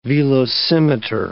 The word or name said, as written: velocimeter